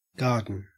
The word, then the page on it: garden: Australian English